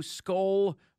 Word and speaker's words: skol: Skol Vikings. Diesel, what does skol mean? – It's a Viking term. – Am I saying that right? Skol? – Yeah, skol.